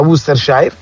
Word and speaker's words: Worcestershire: Quella che si scrive Worcestershire, esatto. – E si pronuncia Worcestershire.